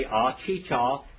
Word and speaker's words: Achicha: Okay, Achicha, A-C-H-I-C-H-A. And then the last one is M-I-Y-A-D-E-C-H-A.